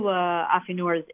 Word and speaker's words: affineur: So let's see, in the French, is it affineur? A-F-F-I-N-E-U-R? – Right, yep, affineur practices affinage,